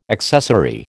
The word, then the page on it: accessary: American English